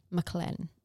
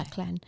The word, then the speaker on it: McLachlan: It's spelled M-C-L-A-C-H-L-A-N. McClane. – Okay. – McClane.